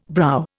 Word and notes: brow: Indian

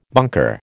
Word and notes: bunker: American English

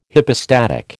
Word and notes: hypostatic: American English

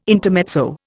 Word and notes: intermezzo: Indian

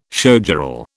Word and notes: showgirl: British English

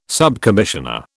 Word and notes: subcommissioner: British English

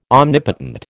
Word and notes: omnipotent: American English

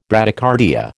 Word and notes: Bradycardia: American English

